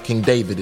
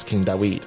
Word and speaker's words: Dawid: So it's not King David, it's King Dawid.